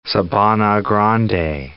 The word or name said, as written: Sabana Grande